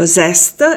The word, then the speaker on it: zest: Si pronuncia meglio zest e poi capiremo da dove è tratta.